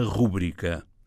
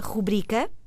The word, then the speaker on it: rubrica: A palavra rúbrica com acento no U não está consagrada em português. A palavra é grave, ou seja, o seu acento tónico recai na penúltima sílaba, rubrica, não tem acento, e serve para tudo.